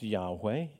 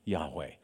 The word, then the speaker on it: Yahweh: But the name of God, it's probably pronounced Yahweh in English.